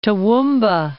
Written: Toowoomba

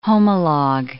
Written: homolog

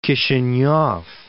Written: Kishinev

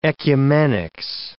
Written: ecumenics

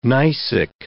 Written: gneissic

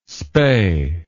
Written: spae

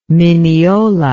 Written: minneola

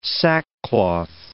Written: sackcloth

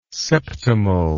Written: septimal

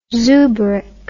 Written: zoobrick

